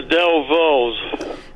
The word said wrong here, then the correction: Dovells: I'm yeah, not saying it right. – That's all right. You know it's the Dovells, and I'll give it to you for that.